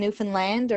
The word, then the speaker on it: Newfoundland: It's like understand Newfoundland or something like that. And that's how you get people to remember how it's actually pronounced.